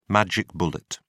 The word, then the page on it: magic bullet: British English